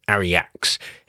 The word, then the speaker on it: Aryaxz: We started this week with the sounds of Aryax, A-R-Y-A-X-Z. That's what I'm going with on the pronunciation anyway.